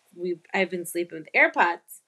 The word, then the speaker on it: AirPods: AirPods? Am I saying it right? Yeah. I always call them earpods, and Liv just. I get mad. – Cringes. – I get mad. she cringes when I say earpods,